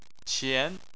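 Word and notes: qian: tone 2

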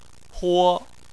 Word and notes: huo1: chinese

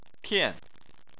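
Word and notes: tian: tone 4

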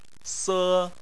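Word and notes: se: tone 1

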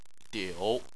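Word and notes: diu: tone 3

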